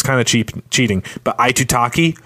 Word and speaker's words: Aitutaki: Aitutaki. Aitutaki.